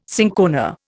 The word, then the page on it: cinchona: Indian